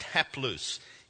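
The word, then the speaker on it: haplous (ἁπλοῦς): Now, it's fascinating to me that the word in Greek for healthy is haplous. It's pronounced haplous.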